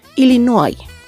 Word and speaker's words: Illinois: En inglés, el nombre del estado de Illinois se escribe exactamente igual que en español, pero se pronuncia de manera muy distinta debido a su origen francés. Escritura Illinois, pronunciación Illinois.